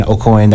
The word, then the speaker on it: Aucoin: And I'm on all the socials as Evan O'Coin. That's O'Coin spelled the French way, A-U-C-O-I-N.